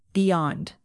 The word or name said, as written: beyond